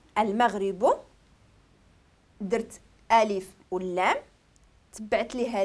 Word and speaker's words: المغرب: هنا ما عرف عندي هذه هنايا المغرب، ملي كنقول المغرب لا لا، الميم راه حرف قمري ماشي حرف شمسي. المغرب كنقولو كنطقو الا وكنطقو اللام.